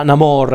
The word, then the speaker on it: Namor: ovvero Na- Namor, anzi qua lo chiamano con questo accento,